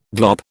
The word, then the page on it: glop: British English